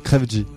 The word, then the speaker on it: Crève-G: FDG, c'est ça, je prononce bien? – Pas du tout. – Alors c'est à l'envers. – G-F-G. – DJ Frey. Non. – Non, Crève-G. – Crève-G. Bonsoir, Crève-G. – Ouais. Voilà. C'est bien prononcé. – Qui postillonne quand il prononce son nom. – Toujours. – M-A-T, à sa droite.